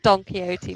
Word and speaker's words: Don Quixote: Oh, that Don Quixote moment. – Oh. No, you're good. That's how you say it.